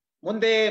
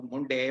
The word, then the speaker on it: ಮುಂದೆ: ಆದರೆ ಈ ಇಂಗ್ಲಿಷ್ನ ಡಿ ಉಚ್ಚಾರ ಮತ್ತು ಕನ್ನಡದ ಡ ಉಚ್ಚಾರದ ಮಧ್ಯೆ ಇರುವಂತಹದ್ದು ದ ಅದನ್ನ ನಾವು ಹೇಗ್ ಬೇಕಾದರೂ ತಿಳ್ಕೊಳ್ಬಹುದು ಅವನಿಗೆ ಅನ್ಕೊಂಡದ್ದು ಅವ ಅನ್ಕೊಂಡದ್ದು ಮುಂದೆ ಮುಂದೆ ಅಂತ ಬಟ್ ಬೇರೆಯವರಿಗೆ ಕೇಳಿಸಿದ್ದು ಮುಂಡೆ ಮುಂಡೆ ಅಂತ